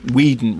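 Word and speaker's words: Winding: Now, the director, spelt Nick Wingding Refn, but I believe pronounced Nick Whedon Refn, who I think is the son of Andus Refn, the editor.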